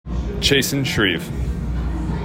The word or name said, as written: Chasen Schreve